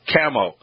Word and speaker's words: CAMO: Let's talk about the CAMO, the C-A-M-O, the CAMO. Am I pronouncing it correctly? – You sure are, yep.